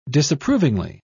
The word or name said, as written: disapprovingly